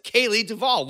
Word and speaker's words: Kalie Duvall: It's Kalie Duvall.